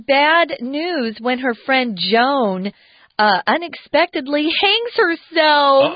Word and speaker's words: Joan: I should say J-O-A-N is pronounced Joan most of the time, but I have met people here in Taiwan who insist on pronouncing it as Joanne. I don't know why, but that's pretty rare. Most of the times it's Joan. My aunt's name was Joan, so I would know. – Joanne is spelled differently, J-O-A-N-N-E, or with an E at the end, J-O-A-N-N-E, J-O-A-N-N.